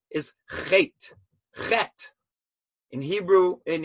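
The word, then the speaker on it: chet (חטא): Spelled with three letters. Ches, tes, aleph. An aleph at the end is not pronounced. You put two dots under the chet and it's pronounced chet, period.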